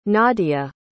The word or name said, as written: nadia